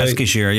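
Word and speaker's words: Eskişehir: In Eskishir, I think I'm saying that right. – Eskishir, yeah, that's how I would pronounce it.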